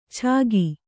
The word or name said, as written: ಚಾಗಿ